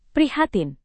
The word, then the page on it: prihatin: Indonesian